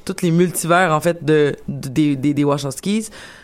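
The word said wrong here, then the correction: Wachowski: Je le prononce pas comme il faut, hein. – Wachowskis.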